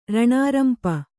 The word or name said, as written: ರಾಣಾರಂಪು